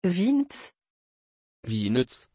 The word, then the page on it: vinzent: French